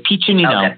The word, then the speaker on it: Piccinino: It's Piccinino. Okay. So it's Italian. Okay. So the double C's make a CH sound. So yeah, it's just a pitch or peach.